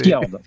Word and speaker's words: Fjord: Genre, je ne sais pas trop comment ça se prononce. Hein, c'est... Fjord. – Ouais, Fjord, merci.